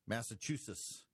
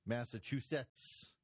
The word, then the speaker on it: Massachusetts: I had a listener a little while ago text me and said I needed to make sure I pronounced the T's in Massachusetts. Massachusetts. Massachusetts.